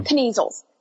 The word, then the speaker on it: Kneasel: Kneasel or Kneasel? – I usually pronounce the K, but I don't know. – Kneasels.